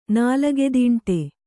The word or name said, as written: ನಾಲಗೆದೀಂಟೆ